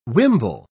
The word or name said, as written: wimble